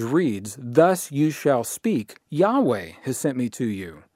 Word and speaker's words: Yahweh: However, the overwhelming majority of scholarly opinion believes that the V is pronounced as a W. And in English, if we are to write this now from left to right, YHWH, it is believed that the name was pronounced as Yahweh, Yahweh, Yahweh.